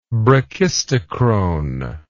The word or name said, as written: brachistochronic